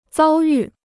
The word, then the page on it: 遭遇: zāo yù